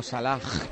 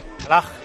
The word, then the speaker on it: Salah: Qué bien has pronunciado, Salah. – Salah.